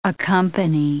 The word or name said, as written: accompany